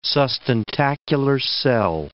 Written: sustentacular cell

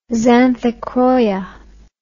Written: xanthochroia

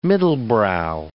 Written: middlebrow

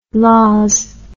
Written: laws

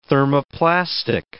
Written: thermoplastic

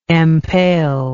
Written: empaler

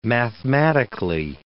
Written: mathematically

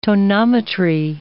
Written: tonometry

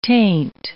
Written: taint

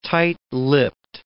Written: tight-lipped

